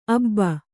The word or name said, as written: ಅಬ್ಬಿ